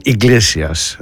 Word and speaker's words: Iglesias: Ο Enrico Iglesias, έτσι τον προφέρουν, Iglesias, στο Hero, σε ένα τραγούδι που κυκλοφόρησε το 2001, λίγες μέρες πριν από τα γεγονότα της 11ης Σεπτεμβρίου.